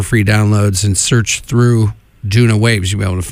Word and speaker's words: Doona: They're called Duna Waves. Duna is spelled D-O-O-N-A.